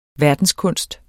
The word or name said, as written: verdenskunst